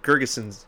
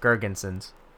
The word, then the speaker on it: Girgensons: Okay, Zemgus Girgensons. – Wrong. – What is it? – You got the first name Zemgus. – Right. Zemgus. Girgensons.